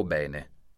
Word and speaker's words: bene: Bene o bene? La risposta giusta è bene. Nella parola bene, l'accento fonico della E è grave, quindi la E è aperta.